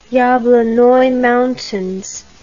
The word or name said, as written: yablonoi mountains